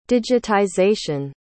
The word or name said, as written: digitization